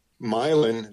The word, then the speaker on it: Milan: Milan, they pronounce it Milan, spelled Milan, but it's where Thomas Edison was born, kind of near there.